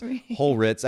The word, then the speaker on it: Hulritz: But yeah, I end up having to create some type of frame of reference for people, even though it's like phonetically, it's not actually that complicated to pronounce. Hulritz. I usually go with the Ritz crackers reference.